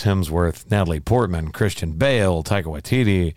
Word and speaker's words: Taika Waititi: Taika Waititi, I believe is how his name is. So I got his name right.